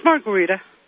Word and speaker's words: Margarita: Did I say that right? – It's Margarita. – Margarita. – As ooh, it sounds like a drink to me. – Yeah, just like that.